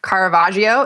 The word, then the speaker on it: Caravaggio: But what I did also want to share, a little art history lesson, is this Caravaggio.